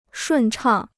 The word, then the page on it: 顺畅: shùn chàng